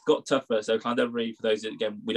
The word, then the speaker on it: Llandovery: Yeah, Llandovery, but yeah, you're all right.